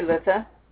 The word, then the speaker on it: Litha: And for those of us who follow a wheel of the year, it is called Litha. Does anyone else pronounce it differently? Do you? – Oh, no, I say Litha.